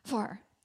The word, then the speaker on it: Hvar: It's H-V-A-R, but there's a very particular pronunciation, which I have. Okay, you. Hvar. – Yeah, it's like it's almost like you sneeze a little bit. You're like, Hvar.